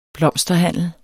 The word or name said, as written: blomsterhandler